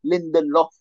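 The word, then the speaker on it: Lindelöf: You got Lindelof, always pronounced wrong. Yeah, finally. – Lindelof, that's the one.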